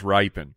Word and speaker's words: Rypien: Rypien. – If he's ripping the ball, do you still like? – It's pronounced Rypien.